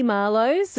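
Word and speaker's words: The Marloes: The Marlowes, I'm pronouncing it that way, excuse me, because it's spelled T-H-E-E, Marlowes, M-A-R-L-O-E-S, if you're looking it up online to listen to those guys.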